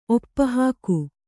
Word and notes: ಒಪ್ಪಹಾಕು: oppahāku

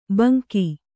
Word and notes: ಭಂಗಿ: Kannada

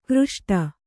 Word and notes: ಘೃಷ್ಟಿ: kannada

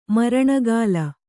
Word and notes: ಮರಣಗಾಲ: maraṇagāla

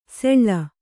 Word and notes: ಸೆಳ್ಳ: seḷḷa

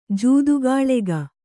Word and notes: ಜೂದುಗಾಳೆಗ: kannada

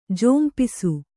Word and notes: ಝಂಪಿಸು: jhampisu

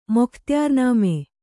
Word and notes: ಮೊಖ್ತಿಯಾರ್‍ನಾಮ: mokhtiyār nāma

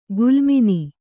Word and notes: ಗುಲ್ಮಿನಿ: Kannada; gulmini

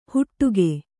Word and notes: ಹುಟ್ಟುಗೆ: huṭṭuge

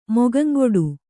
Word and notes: ಮೊಗಂಗೊಡು: Kannada

